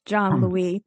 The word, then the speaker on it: Jean-Louis: Almost. It's John um, Louis, but that's all John right.